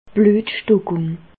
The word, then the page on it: d' Blüetstockùng: Alsatian; Bas-Rhin; Reichshoffen